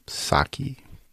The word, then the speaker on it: Psaki: But uh, he's not a fan of former White House press secretary Miss Jen Psaki, P. Saki. Because remember, the, the P is silent for purpose.